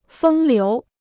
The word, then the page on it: 风流: fēng liú